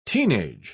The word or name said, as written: teenage